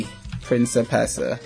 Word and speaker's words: Principessa: The word was it's Principessa. I'm gonna type it here. Let me see. It's P. – Yeah, yeah. – It's P R I N C I P E S S A Principessa.